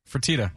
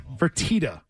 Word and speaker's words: Fertitta: It's Fertitta. – Fertitta.